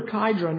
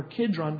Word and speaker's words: Kidron: They said that the river Kidron or Kidron, depending how you pronounce it, the river that ran through Jerusalem would turn red.